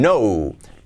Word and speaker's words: know: La T de didn't no se pronuncia, didn't know. No es como no, no, sin la K, no existe ese sonido.